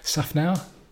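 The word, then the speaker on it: Szafnauer: And, you know, he was, because they were asking him as well, because apparently, who's the Alpine, the boss, Otmar Schaffernacker? – Schaffnauer? – Okay, that's probably a more less offensive pronunciation.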